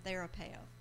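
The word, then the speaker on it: Therapeuo (θεραπεύω): Am I saying that right? Therapeuo. Mm-hmm. Okay, thank you.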